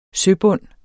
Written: søbund

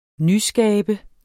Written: nyskabe

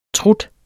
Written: trut

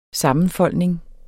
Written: sammenfoldning

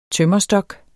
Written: tømmerstok

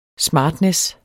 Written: smartness

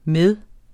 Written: med

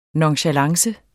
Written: nonchalance

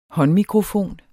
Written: håndmikrofon

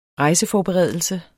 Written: rejseforberedelse

